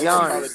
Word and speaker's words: Giannis: Bro, it's Giannis. Easy, it's Giannis. – Giannis. I'm gonna keep saying it wrong just to aggravate shit at you.